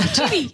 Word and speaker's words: chibi: A chibi.